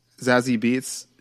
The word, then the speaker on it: Zazie Beetz: Zazie Beetz, is that how you say it? – Yeah, Zazie. – Zazie Beetz.